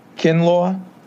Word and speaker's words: Kinlaw: Nine, with the Jaguars, Javon Kinlaw, if I'm saying that correctly. – You are.